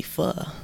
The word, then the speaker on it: pho: Like my favorite food right now is probably pho. You know what that is? – Yeah, it's a Vietnamese noodle type dish. You pronounced it correctly.